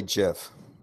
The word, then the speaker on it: GIF: Because it's clearly GIF. – It's GIF stands for graphic interchange format. GIF. It's a hard G.